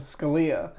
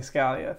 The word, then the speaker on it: Scalia: So the correct way to say it is the way he pronounced it, which is Scalia. But to distance myself from him, I say Scalia.